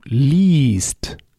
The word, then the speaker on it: liest: Du liest. You see, here we switch the vowel. Lesen with an E. Du liest with a long I that is written I-E.